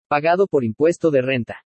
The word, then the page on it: PAGADO POR IMPUESTO DE RENTA: spanish